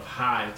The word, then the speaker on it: Hye: Here's a small quick plug for the town of High, Texas, and you spell that H-Y-E.